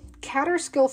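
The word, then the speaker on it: Kaaterskill: So Catterskill Falls, most people don't know that this stunning waterfall is haunted by a dog. You're probably familiar with Catterskill Falls. If you're not, it's spelled K-A-A-T-E-R-S-K-I-L Falls.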